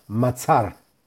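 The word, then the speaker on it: matzar (מצר): Y es el santo nombre de Mem Tzadik Reish y se pronuncia matzar.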